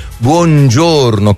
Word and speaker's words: buongiorno: Buongiorno, con la O chiusa.